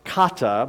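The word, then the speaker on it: kata (חטא): Number one, it's pronounced kata, but it looks like hata.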